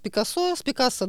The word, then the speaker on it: Пикассо: На самом деле, как вот ситуация с Пикассо, да, вот по-разному произносят, на самом деле, по-моему, на второй слог нужно, да, потому что он не француз.